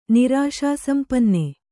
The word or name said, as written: ನಿರಾಶಾಸಂಪನ್ನ